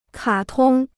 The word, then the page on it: 卡通: kǎ tōng